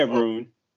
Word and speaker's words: Rune: Uh, either Garin wasn't feeling it today, or this whole room was there's a Runa. Uh? – Yeah, Rune. Yeah, it's Rune. Yeah, Holger Rune. You said it right.